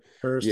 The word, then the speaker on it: Hoest: And then we have, I don't know how you say his name, Hoest of Taka, T-A-A-K-E. – Yeah, I don't know if it's Hurst or... – Hurst.